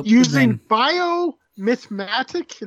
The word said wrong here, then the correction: biomimetic: is that how it's pronounced biomimatics and micro robot technology i'm not i'm not brave enough to try and pronounce it that's um, what I was looking for. – It's um, biomimetic.